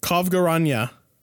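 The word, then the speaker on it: Kovgorenya: Kovgaranya. – Where's the V? – Where's the G also? So that's the actual pronunciation.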